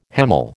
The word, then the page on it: hemal: American English